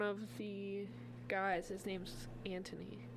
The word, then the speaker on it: Anthony: Is it Anthony or Antony? – Well, the way they pronounced it back then was Antony.